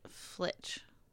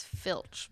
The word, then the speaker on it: Filch: Well, it's Filch, but I was saying Flitch in my head.